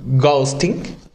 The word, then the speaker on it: ghosting: Hay otra palabra que también se ocupa que es la de ghosting. Sí, lo pronuncié bien. Ghosting.